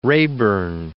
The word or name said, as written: Rayburn